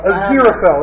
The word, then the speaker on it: Aziraphale: My favorite part of the book, or basically my favorite characters, were Crowley and, Azrael? – Azrael, yeah. Azrael. – Azrael. – Azrael. – I read it as Aziraphale. – Aziraphale, that's how they pronounce it.